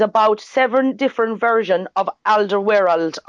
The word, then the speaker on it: Alderweireld: Which would be Alderweireld.